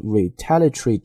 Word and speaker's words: retaliatory: retaliatory 这个单词来自于 retaliation and retaliate, 表示的意思叫做报复。当然呢，我们按它的这个字母去进行拼读的时候，完全可以把那个 retaliatory 都拼出来。但是呢，英语在读的过程当中，这个 terry 啊，经常容易把它缩读成 tree，所以就像 factory，我们经常读成 factory 一个道理。所以这是 retaliatory tariffs。